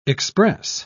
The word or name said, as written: express